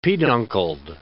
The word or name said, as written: peduncled